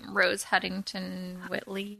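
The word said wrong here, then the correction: Rosie Huntington-Whiteley: Oh, Rosie She's- Huntington Whiteley.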